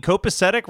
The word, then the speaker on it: copacetic: I mean, copacetic rhyming with pathetic.